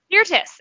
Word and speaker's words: Sirtis: Dr. Markov is played by the amazing, I hope I'm saying her name right because I've only ever seen it written, but Marina Sirtis? – Sirtis. – Okay. Yes. Yeah, yes.